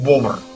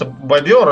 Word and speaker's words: бобр: Ну, в общем, а, да, еще правильно говорить бобр. – Бобр, да. – Потому что бобер это все-таки это мех, так называют. Но ну, на самом деле бобер это просто просторечие, которое было решено э, мех именовать отдельно.